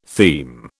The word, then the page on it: theme: British English